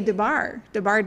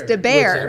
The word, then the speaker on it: DeBair: I say DeBar Design, and it's not. It's DeBair. – Everybody does. – For Barry. – Yes, it's the first syllable of my name.